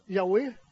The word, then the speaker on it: Yahweh: YHWH, qu'on prononce Yahweh, mais c'est un nom imprononçable.